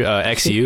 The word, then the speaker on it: Xu: Is that how you pronounce it? – Tony Xu, X-U, Xu.